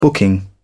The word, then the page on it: booking: British English